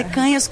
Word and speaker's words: Canhas: Canhas, picanha, o cara nunca mais esquece, mas é Canhas com N com tio, e as redes são Ana, _ Canas.